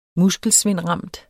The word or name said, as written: muskelsvindramt